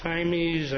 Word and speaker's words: Jaimes: And the briefs rely on Jaimes, I think from my high school Spanish, that's the correct pronunciation, Garrett and Allen, which we would submit are basically all force precedents for this case.